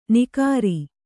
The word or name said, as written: ನಿಕಾರಿ